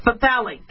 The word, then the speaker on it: phthalate: It's phthalate. That's how you pronounce it. I do have a lisp and a stutter, but I said that one right.